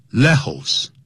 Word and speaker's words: Lejos: 只有少数像呢 J 你要念成不能念成 J 也念成 H 的音所以呢 Lejos L-E-J-O-S 这是西班牙文不能念成 Lejos